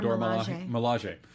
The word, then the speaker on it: Dora Milaje: They showed a lot, apparently, of the Dora Milaje. I'm saying that correctly. – Dora Milaje.